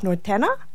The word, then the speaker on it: norteña: norteña. Is that how I pronounce it? – Norteña. – Norteña. Okay, thank you.